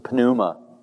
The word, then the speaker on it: pneuma: Yes, you pronounce the P in Greek. The pneuma.